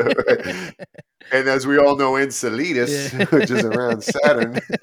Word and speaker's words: Enceladus: Enceladus, you moron!